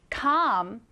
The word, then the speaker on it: calm: L은 거의 소리가 나지 않습니다. 그래서 그냥 calm 이렇게 읽어요. 자, 스펠링을 기억하시고 쌤이랑 같이 오른쪽에 정확하게 써보겠습니다. calm, C-A-L-M.